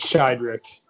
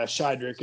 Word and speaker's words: Sheiderich: just yeah, Shidrick. It's pretty much just how it looks. Um, it's pronounced just how you would expect it to be if you if you look at it.